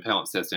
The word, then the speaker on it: Palimpsest: And Palimpsest, for all the trouble people have saying it and writing it down, it literally is exactly as it sounds and pronounced exactly as it's spelled, P-A-L-I-M-P-S-E-S-T, Palimpsest.